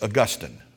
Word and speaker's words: Augustine: Sometimes we in the South pronounce it Augustine, but it's Augustine.